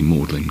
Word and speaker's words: Magdalen: Magdalen College, Oxford, is actually Magdalen College, and Gonville and Chaos, Cambridge, is Gonville and Keys.